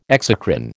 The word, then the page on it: exocrine: American English